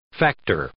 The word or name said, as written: factor